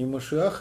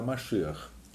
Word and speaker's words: Машиах: Не надо ударение путать, не Машиах они говорят, а Машиах.